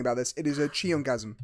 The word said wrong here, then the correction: Cheongsam: Cheongsam, not Cheongasm. It's not a Cheongasm, it's a Cheongsam.